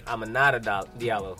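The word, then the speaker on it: Aminata Diallo: Amanita Diallo? – It's women. – Ah. Amanita. Wait. Is it Amanita? Is that how you say her name? Diallo? – Amanita Diallo, yeah.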